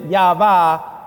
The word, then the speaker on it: Yahweh: Now, what we do is we pronounce it Yahweh, but that they didn't use the put the vowels in.